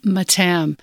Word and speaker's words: Mattam: Mattam. That's the sort of anglicized version. – It's the anglicized version.